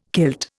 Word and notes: kilt: Indian

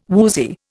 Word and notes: woozy: Indian

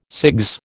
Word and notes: CIGS: American English

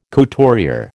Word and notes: couturier: American English

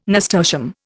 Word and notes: nasturtium: Indian

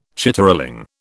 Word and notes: chitterling: British English

